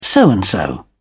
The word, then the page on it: so and so: British English